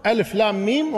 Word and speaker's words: ألم: كلها تكتب ألم، لكن هذه ألم تلفظ ألف لام ميم، وهذه ألم تلفظ إيش؟ ألم.